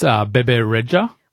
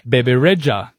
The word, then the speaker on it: Bebe Rexha: Yes, Bebe Rexha.